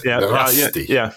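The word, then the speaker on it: Mărăști: and I'm not even sure that I can pronounce it, Marasti, on the 22nd. That's correct. – I think it's Marasti.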